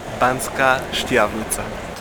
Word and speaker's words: Banská Štiavnica: Banská Štiavnica.